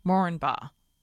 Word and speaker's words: Moranbah: and he said the accepted way to pronounce the name is Moranbah. The first A is not stressed.